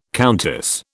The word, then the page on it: countess: British English